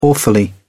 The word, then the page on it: awfully: British English